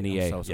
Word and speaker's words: INEA: So they're going to probably present on a little bit what an INEA is. Did I say that right? – INEA, yes.